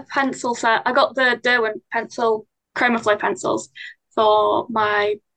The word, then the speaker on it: Derwent: So when did you, or how did you come about being interested in, I want to make sure I'm pronouncing it right. It's Derwent, right? – Derwent? Yes, Derwent, yeah.